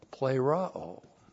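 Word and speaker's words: plerao (πληρόω): Here's the word for fulfill, plerao. P-L, long E-R-O, long O, plerao is the verb for fulfillment, to fulfill, to be fulfilled.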